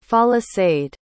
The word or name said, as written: folasade